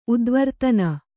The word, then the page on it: ಉದ್ವರ್ತನ: udvartana